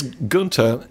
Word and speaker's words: Gunter: Maybe Chris Gunter has a future there. – That's possible. – Gunter. – Relax. – Now, boyo. Chris Gunter rhymes with Junta, not Hunter. – No, I think it rhymes with Hunter, Does it? – Yes.